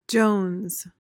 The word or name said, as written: jones